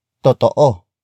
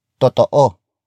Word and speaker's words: totoo: Totoo. Totoo. Totoo is spelled as T O T O O.